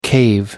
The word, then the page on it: cave: American English